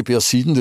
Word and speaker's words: Perseiden: Aber es gibt schon immer mehr solche Ereignisse wie die Perseiden, habe ich das richtig gesagt? – Ja, die Perseiden, das ist ein Highlight jedes Jahr.